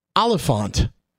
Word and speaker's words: Olyphant: Can I say how it's spelled? – Yeah. – It's spelled Olyphant. – Now, I would say Timothy Oliphant. – I've pronounced it and I've heard it pronounced Timothy Oliphant. Oliphant.